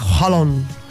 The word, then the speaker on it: Haaland: Claro, como el tema de la pronunciación de Erling Haaland, y no es Haaland, es Holland. Holland, no sé cómo es.